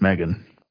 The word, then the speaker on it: M3GAN: Today, we're talking about M3GAN, or I guess you're supposed to pronounce it Megan.